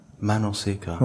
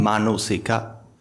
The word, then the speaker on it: manosika: Manosika. – Or manosika Pali. Manosika Pali. – Manosika. – That's how it's spelled. M-A-N-O-S-I-K-A.